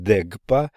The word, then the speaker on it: дукпа: Дукпа, примечание, в Тибете слово «дукпа» вызывает неодобрительное отношение, сами они произносят его как «дегпа» от корня «связывать».